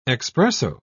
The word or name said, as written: expresso